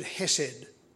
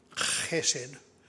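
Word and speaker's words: chesed (חסד): This word hesed, or to pronounce it properly, chesed, is most frequently found in the Psalms, but also comes in Hosea and in Jeremiah chapter 31.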